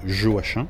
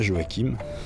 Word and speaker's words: Joachim: au prénom dont la prononciation ne met pas tout le monde d'accord, mais moi, comme tout angevin qui se respecte, j'honore le poète de ma région en prononçant son prénom Joachim, et pas Joachim, mais en réalité les deux sont admis.